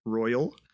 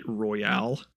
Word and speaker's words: Royal: I'm still knocking out Persona 5 Royal. I said it right that time. I have a really bad habit of calling it Royale.